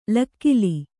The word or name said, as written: ಲಕ್ಕಲಿ